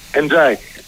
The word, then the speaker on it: Εντζάι: Έχουμε καταλήξει σε αυτή την προφορά. – Ναι, το είπε ο ίδιος, νομίζω. – Εντάξει. – Θυμάσαι, Παναγιώτη, έναν Εντζάι που είχε παίρνει το Πανελλήνιος κάποτε. – Θυμάμαι. – Τον λέγαμε Ενδιαγέστη. – Ναι, θυμάμαι. – Βγήκε μαζί από την Εντζάι. Προφανώς αυτό έτσι προφέρεται.